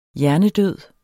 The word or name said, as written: hjernedød